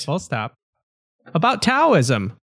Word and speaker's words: Taoism: Yes, it can be spelled with a D. It can be spelled with a T. Regardless, it is pronounced Taoism.